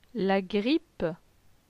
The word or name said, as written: La grippe